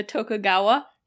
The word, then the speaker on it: Tokugawa: The Tokugawa.